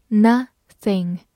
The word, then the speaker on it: nothing: n o 发 n，t h 注意咬舌，i n g in nothing nothing，注意不要读成 nothing nothing，它是一个代词，表示没有什么。